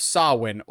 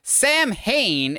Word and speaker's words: Samhain: Samhain or Sam Hane, if you're Donald and don't know how to properly pronounce the word,